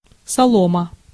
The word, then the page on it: straw: /strɔː/; [strɔː]